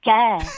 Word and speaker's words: cœur: Oui, dans votre façon de parler, rien quand vous dites cœur. Nous, on dit cœur, il est fermé. Vous, vous dites cœur, il est ouvert.